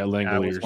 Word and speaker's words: Langeliers: So, and Langeliers, is that how you pronounce it? – Yeah, Langeliers was one of them.